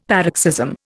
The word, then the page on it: paroxysm: Indian